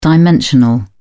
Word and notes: dimensional: British English

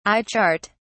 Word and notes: eye chart: British English

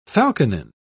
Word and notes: falconine: British English

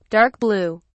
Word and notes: dark blue: British English

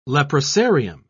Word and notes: leprosarium: British English

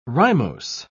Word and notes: rimose: British English

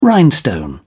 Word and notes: rhinestone: British English

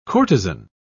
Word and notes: courtezan: British English

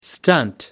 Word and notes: stunt: American English

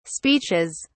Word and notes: speeches: British English

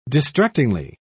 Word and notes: distractingly: British English

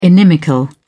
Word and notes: inimical: British English

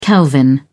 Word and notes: kelvin: British English

